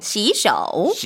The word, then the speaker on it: 洗手: 근데 발음은 시소 하면 안 되죠. – 네, 시소. – 자 시세자 시. – 시. – 그다음에 손 숫자예요. 쇼. – 쇼. – 그렇죠. 여우 발음 해주면 좋아요. 쇼. – 쇼. – 시쇼. – 시쇼.